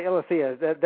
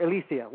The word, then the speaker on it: Eileithyia: Yes, you can say Eileithyia, that's fine, or Eileithyia. Either way is fine.